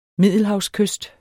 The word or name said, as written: MIDDELHAVSKYST